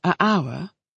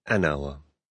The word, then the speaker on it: hour: Is it right to say a hour or an hour? – It's right to say an hour. – Why? – Because the letter H in the word hour is silent.